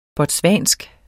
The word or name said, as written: botswansk